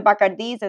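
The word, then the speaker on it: Bacardi: And that's significant because really until this lawsuit was issued or took place, the Bacardis were very happy, or the Bacardis, as we correctly pronounce it, were very happy to sort of, take the credit for everything.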